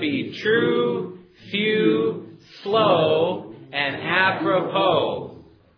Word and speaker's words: apropos: I want you to say that top line with me, but before we do, notice the pronunciation of that last word. It is apropos. Apropos. I don't know what language that comes from with the S on the end, but apropos means to the point, pertinent, and at an opportune time.